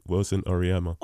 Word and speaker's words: Wilson Oryema: um, is that how it's pronounced? – Uh, you can choose. – No, that. no, no, no. Tell me how you would like it to be pronounced because I'm fussy about my name. So okay, Wilson Oryema.